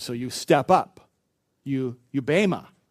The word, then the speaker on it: bema: The Greek word for judgment used here, you may have heard this before, it's bema. It's spelled B-E-M-A. It's pronounced bema.